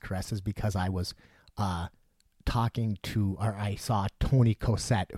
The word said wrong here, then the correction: Coser: who is a guy who used to come, or Coser, that's how you say his name, who used to come into the Eclectic back in the day.